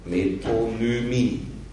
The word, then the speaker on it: Metonymie: Metonymie.